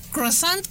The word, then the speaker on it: croissant: En el croissant, que no lo pronuncio bien. – ¿Cómo se pronuncia? – Croissant. – Croissant. – Croissant. Ah, muy bien.